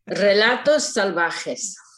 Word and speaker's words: Relatos Salvajes: Relatos Salvajes.